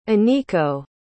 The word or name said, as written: Aniko